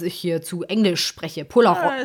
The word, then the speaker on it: Polaroid: Das ist schön, wie du es Englisch aussprichst. Polaroid.